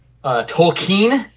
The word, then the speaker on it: Tolkien: Tolkien, apparently, is the correct pronunciation of his name, J.R.R.